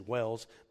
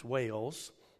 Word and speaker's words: Wales: I've been told by Charity I don't pronounce Wales right. I say Wells, but it's Wales.